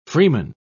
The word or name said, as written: freeman